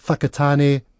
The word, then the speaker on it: Whakatane: But just so that things aren't so complicated, if you see a WH sound, it's pronounced as F. So Whakatane, Whangarei, and if you want to, Whanganui.